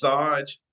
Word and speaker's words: Sayge: And of course, that is spelled S-A-G-E. But since my name is S-A-Y-G-E and pronounced Saj, I get called Sage all the time. – So I bet. – Whether you say Saj or Sage, you're still talking about me, so I just named the law firm Sage.